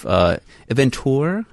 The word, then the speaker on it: eventeur: She's the chief eventeur, is that how you pronounce it? – Yes, parfait.